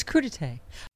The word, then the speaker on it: crudité: I like the fact that there's been a vigorous Twitter debate on how to pronounce it. – Crudité. – Yeah. Crudite. – It's crudité.